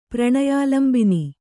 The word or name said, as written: ಪ್ರಣಯಾಲಂಬಿನಿ